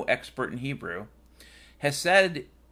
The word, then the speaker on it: Hesed: You know, last week in service, our minister talked about, and I want to pronounce this correctly, Hesed, and I believe that's in English spelled Hotel Echo Sierra Echo Delta. Yeah, Hesed.